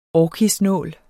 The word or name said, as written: orkisnål